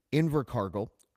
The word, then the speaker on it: Invercargill: In December 2007, not long after my ultimate confession, I was ushered to a consultation with Hales, where he visited Invercargill.